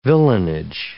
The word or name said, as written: villenage